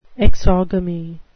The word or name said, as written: exogamy